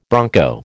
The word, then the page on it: bronco: American English